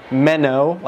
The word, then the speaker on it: Mennonite: Then they're like, no, let's call them the Mennonites. So it's actually a curse word. So how you pronounce it is like Menno, like a Menno, like a Menno. Menno, night, like night as in nighttime.